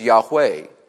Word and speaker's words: Yahweh: It's represented by what we would recognize in the English if we were to transliterate YHWH, and sometimes it's pronounced Yahweh.